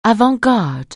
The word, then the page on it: avant-garde: anglais